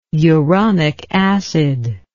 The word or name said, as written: uronic acid